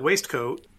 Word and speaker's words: waistcoat: What about W-A-I-S-T-C-O-A-T? – Looks like waistcoat. – Yes. We give two pronunciations in our dictionary. The British pronunciation is waistcoat, not unlike biscuit.